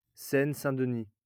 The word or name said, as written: Seine-Saint-Denis